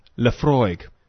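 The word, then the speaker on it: Laphroaig: And so we just Google it, and a YouTube video comes up, and it's just a guy going, Lafroig.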